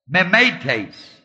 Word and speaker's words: mimetes: It's spelled M-I-M, long E, T, long E-S, mimetes.